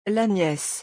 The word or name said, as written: La niece